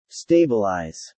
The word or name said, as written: stabilize